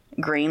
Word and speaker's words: Greene: So my name is spelled Leta, Greene, like the color with an E on the end.